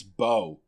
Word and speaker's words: Beau: One of those things that maybe this is my English major speaking, but Beauline, it's Beau. B-E-A-U is Beau. That's how you pronounce that word.